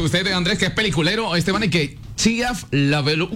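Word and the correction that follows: Shia LaBeouf: ¿Lo dije mal? – Chiaf Lefou.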